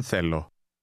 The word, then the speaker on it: θέλω: Did you hear the two different TH sounds? Listen and repeat to practice your pronunciation. – Θέλω. Δεν. Δεν θέλω. – It's like the difference between the words then and think.